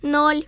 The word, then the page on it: 0: Child